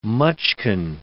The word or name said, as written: mutchkin